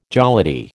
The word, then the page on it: jollity: American English